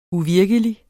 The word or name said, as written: uvirkelig